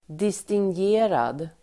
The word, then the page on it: distingerad: distingg'e:rad